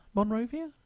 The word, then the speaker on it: Monrovia: However, this has now changed, and the Irish low-cost carrier today flew a Boeing 737-800, I should say, to Liberia's capital in, is it Monrovia? – Monrovia.